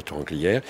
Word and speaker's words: Torrens-Glières: Ça s'écrit Torrens-Glières, mais ça se prononce Torrent-Glières.